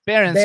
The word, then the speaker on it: Barens: That's so you guys, just so we know who you're talking about, how do you pronounce his last name? Barons? Your last name? Barons. Barons. So Sam Barons, you guys. – B-A-R-E-N-S.